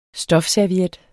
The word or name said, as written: stofserviet